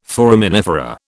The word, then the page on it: foraminifera: British English